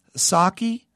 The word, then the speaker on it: Saucke: And so now Stuart Saki, that's how you pronounce his last name, S-A-U-C-K-E, yes.